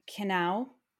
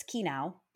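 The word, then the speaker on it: Kenai: Canal Peninsula. It is not Canal Peninsula. It's Kenau Peninsula.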